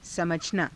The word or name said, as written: samajhanaa